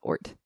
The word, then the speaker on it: Oort: That's O-O-R-T. – Oh, okay. You know how to spell it. Oort.